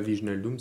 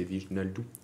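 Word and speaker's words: Wijnaldum: C'est comme c'est pas Viginaldum. c'est Viginaldou.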